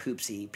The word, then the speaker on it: Pupsi: I believe it's pronounced Poopsie. P-U-P-S-I, right?